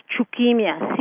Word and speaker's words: Chuquimia: Now, Sylvia, would you please pronounce Kaliseya, I believe is your part of your last name, but would you pronounce C-H-U-Q-U-I-M-I-A? – Yeah, C-A-L-I. Yeah, the other last name is, of course, Chukimia, C-H-U-Q-U-I-M-I-A. – Chukimia is how it's pronounced.